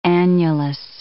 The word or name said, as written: annulus